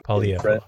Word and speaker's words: Poilievre: Uh, on that note, uh, Pierre Pol- Poilievre, I don't exactly know mm-hmm. how to pronounce his last name. Sorry, Poilievre. Poilievre. Okay.